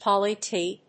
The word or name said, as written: poly T